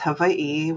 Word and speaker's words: Hawaii: You know, prior to contact, Hawaii, or the proper way to say it is Hawaii, was a self-sustaining, very rich place to live.